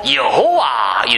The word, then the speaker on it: 여호와: 아니면 이거 자체가 하나님의 이름 해갖고 여기서 그래가지고 이제 자음을 적당히 자음만 있으니까 몸을 넣어갖고 여호와 이렇게 발음하는 사람도 있고 어떤 사람은 야훼 이렇게 발음하는 사람도 있고 한데요.